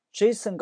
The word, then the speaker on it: Jason: 第三个给大家讲的美句呢，出现在视频里的 12分钟36秒，它这个字幕翻译的是不对的，它说 Jason got a full ride to college，它翻译的是杰森啊一路直通大学，这里面翻译的稍微有一些小问题啊。我们首先来看这个发音，然后再来讲它翻译的问题。先说一个字母的发音，这个字母读 J，很多人翻成 G、 G 都不对，发 J。 Jason， Jason got a full ride to college。